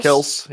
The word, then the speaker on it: Kelce: Kels.